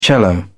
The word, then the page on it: cello: British English